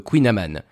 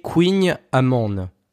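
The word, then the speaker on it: Kouign-amann: L'union de la Bretagne et du beurre a ensuite donné naissance à d'autres beaux bébés, bien gras et bien sucrés, comme le Queen Amman, qui veut littéralement dire gâteau au beurre et qui semble se prononcer Queen Amande.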